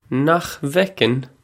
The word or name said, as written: Nach bhfeiceann